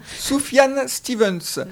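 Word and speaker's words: Sufjan Stevens: Soufiane Stevens. – Ouais. – Tu me parles de la prononciation parce que si vous avez envie d'en savoir plus et chercher sur internet, ça s'écrit S-U-F-J-A-N et Stevens, S-T-E-V-E-N-S.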